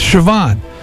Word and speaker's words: Siobhan: Said, Siobhan is spelled C-S-I-O-B-H-A-N. It's of Irish ancestry, like the name Sean, which you think would be spelled differently, but it's spelled S-E-A-N. So I now know it's Siobhan.